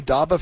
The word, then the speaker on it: Dobba: First up, this says DABA, D-O-B-B-A. Have I pronounced that correctly, DABA from Everett? – Yes, that is correct.